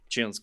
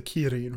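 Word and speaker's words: Qilin: Chilin's cool. – Are you're talking about the Kirin, right? – I pronounce it Chilin. It's debated.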